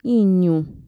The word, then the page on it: iñu: Mixtec